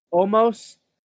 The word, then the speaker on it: Omos: Omos.